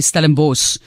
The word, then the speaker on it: Stellenbosch: We say Stellenbosch. It's spelled S-C-H, but you go S in the end.